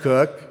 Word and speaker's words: Coke: At about the same time in history as the events I just described, Sir Edward Coke, spelled C-O-K-E, but usually pronounced Cook, obviously no relation, produced the consolidation of the English law in written form.